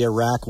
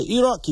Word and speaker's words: Iraq: You have to say Iraq. You can't say Iraq. If you're in the Missoula City Council, you know, you better pronounce it right.